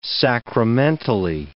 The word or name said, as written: sacramentally